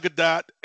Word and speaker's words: Gadot: Gadot. It's Gadot. Yeah. – Okay, because I've heard Gadot, Gadot, Gadot with no emphasis on the T, but it's Gadot.